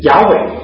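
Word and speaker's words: 亚 伟 (יהוה): 耶和华呢，他原来的名字啊，在希伯来文里面发音就是亚伟。我们这个地方用哈利路亚，亚呢是单音节，就是亚伟的第一个音节，用这个一个音节呢代表整个的字，让这个念的时候呢更加的简洁有力。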